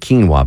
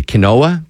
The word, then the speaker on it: quinoa: Like and they don't pronounce it quinoa, by the way. Quinoa, they call it quinoa.